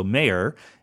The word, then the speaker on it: Mayor: Mayor, I keep saying Mayor, but she says her name is pronounced like the political official. So Mayor,